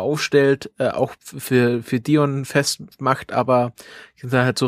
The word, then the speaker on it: Dion: I spricht man wie I, Dion, Dion, Dion.